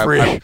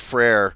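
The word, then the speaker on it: frère: Yeah, yeah, Frere. – I took 2 years of French in high school, so I know it's Frere.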